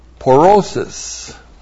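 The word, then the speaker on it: pōrōsis: Now a noun that comes from the verb porao is porosis. P long O R long O S I S. Porosis.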